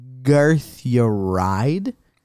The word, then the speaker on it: Garthyaride: Now, the interdimensional time traveler, his name is Garthyaride.